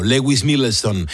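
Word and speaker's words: Lewis Milestone: Muy bien pronunciado, Lewis Millstone.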